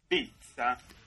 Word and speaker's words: pizza: Ahí dice pizza, la forma correcta de pronunciar pizza en España. – A ver, ahí va. ¿Cómo? – Pizza. Sí, pizza. – Claro. – ¿Con T, ya oyeron? – Sí.